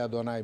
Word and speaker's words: Adonai: E a pronúncia de Adonai é Adonai mesmo? Sim.